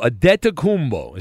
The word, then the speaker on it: Antetokounmpo: Antetokounmpo is how you pronounce it. – Like paying a debt? – It sounds similar to that, but there's no B.